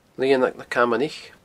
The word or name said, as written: Lianag na Camanaich